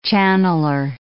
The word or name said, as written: channeler